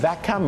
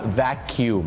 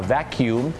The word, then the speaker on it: vacuum: vacuum ไม่ได้ออกเสียงว่า vacuum เหมือนที่พี่น้องชาวไทยบางท่านอาจจะออกเสียงเพราะเข้าใจผิดนะครับเขาจะต้องออกเสียงว่า vacuum นั่นเองครับคล้ายๆแมงมุมขยุ่มหลังคา vacuum หรืออย่างน้อยก็ vacuum สระอู